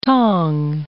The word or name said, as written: tong